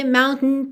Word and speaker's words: mountain: Mountain é uma palavra que tem duas pronúncias diferentes e as duas são bastante comuns. Uma, foi a que eu fiz agora, mountain, com esse tem, tem, tem. E a outra é aquele som para dentro.